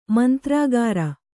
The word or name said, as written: ಮಂತ್ರಗಾರ